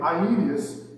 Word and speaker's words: Aeneas: But in verse 33, he says, there he found a man named Aeneas. And yes, you pronounce every single letter of that name, every single letter. There he found a man named Aeneas, and Aeneas had been bedridden for 8 years.